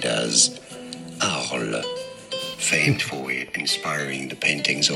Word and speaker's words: Arles: 那呃，上一集我们离开亚维农之后呢，就来到这个呃，就梵谷曾经在这边住过好呃好呃十五个月的这个 Arles。 – 是我们刚我们有查这个怎么读，法文好像叫 Arles。 – Yeah. – 对，所以我之前读错。